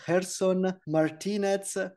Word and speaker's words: Gerson Martinez: His name is Gerson Martinez.